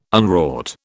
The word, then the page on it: unwrought: British English